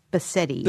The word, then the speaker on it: Bassetti: There's an I at the end, so it's Bassetti.